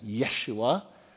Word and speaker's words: Yeshua: The name Jesus, which would have been pronounced in the New Testament Yeshua, is the New Testament version of the Old Testament name Joshua, and it means the Lord saves.